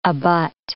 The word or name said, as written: abut